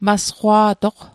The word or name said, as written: masqwa'toq